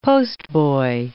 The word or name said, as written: postboy